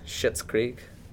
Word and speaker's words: Schitt's Creek: I watched the first episode of, let's see if I can get away with this, Schitt's Creek. Hmm? – That's how you pronounce it.